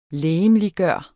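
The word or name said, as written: LEGEMLIGGØRE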